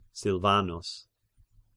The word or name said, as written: Silvanus